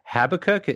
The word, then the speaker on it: Habakkuk: There was a Hugo Award-nominated fanzine, Habakkuk. Am I saying it correctly? – Yes.